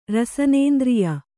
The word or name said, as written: ರಸನೇಂದ್ರಿಯ